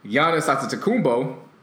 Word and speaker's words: Antetokounmpo: And then Giannis Antetokounmpo, Greek from...